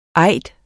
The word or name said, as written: eid